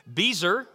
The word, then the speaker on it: Bezer: Bezer, it rhymes with geezer.